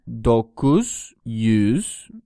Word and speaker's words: dokuz yüz: Dokuz yüz.